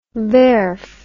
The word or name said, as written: werf